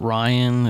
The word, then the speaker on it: Rhian: The actress who plays her, I'm assuming you pronounce it Ryan. It's spelled R-H-I-A-N.